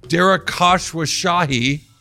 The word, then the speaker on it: Khosrowshahi: The CEO of Uber Technologies, Dara Khosrowshahi. I can say his name, I swear. Dara Khosrowshahi, wonderful guy, super nice guy, at least in my interactions with him.